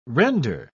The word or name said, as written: render